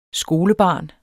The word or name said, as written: skolebarn